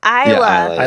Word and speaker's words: Isla: Isla.